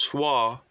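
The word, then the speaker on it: Soil: 1714, the, so- the Soyo Stradivarius, pronounced Swa violin, is created by Italian musical instrument craftsman Antonio Stradivari.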